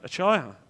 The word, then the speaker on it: Achaia: Achaia, thank you, Achaia.